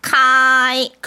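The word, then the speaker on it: 카이 (开): 그래서 과장해서 일성으로 발음하세요. 카이.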